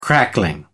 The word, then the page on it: crackling: American English